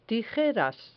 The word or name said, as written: tijeras